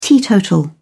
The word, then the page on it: teetotal: British English